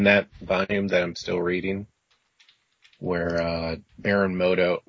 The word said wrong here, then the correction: Mordo: Mordo, I can't pronounce his freaking name. Mordo. He's thank you.